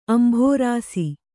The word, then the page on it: ಅಂಭೋರಾಸಿ: kannada